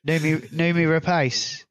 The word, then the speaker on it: Noomi Rapace: Noomi Rapace.